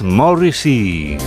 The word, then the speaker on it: Morrissey: Por eso ha creado este número musical llamado Morrissey, o como se pronuncia en inglés, Morrissey.